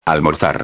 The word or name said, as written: almorzar